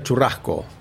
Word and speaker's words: churrasco: And one of the other really interesting one is the tuna charascuro. How do you say? – Tuna churrasco. – Churrasco, yeah. Yeah, churrasco. Churrasco is a beautiful term in our culture.